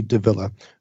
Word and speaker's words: Davilla: Davilla. D-A-V-I-L-L-A.